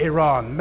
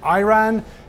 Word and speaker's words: Iran: But first, in the question that he was asked, he was told how to say Iran. Many Americans call it Iran. He was told pretty bluntly, it's Iran.